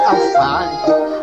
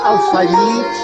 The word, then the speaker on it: алфавит: Нельзя сказать алфавит, а можно алфавит. – Кто говорит алфавит, неверно говорит. Кто говорит алфавит, неверно говорит.